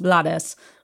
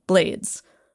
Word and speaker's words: Blades: I should also add that Ruben's last name is commonly pronounced Blades, but he tells us it's actually pronounced the English way, Blades, which is pretty perfect for the guy who wrote a legendary song about Mack the Knife.